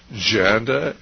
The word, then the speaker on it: Janda: And you want to read about them, why, just go to your search engine and type in Dr. Janda, rhymes with panda, that's Janda, and then, of course, the death panels.